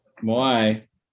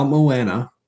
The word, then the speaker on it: Moai: Moai. – Not Moana, Moai.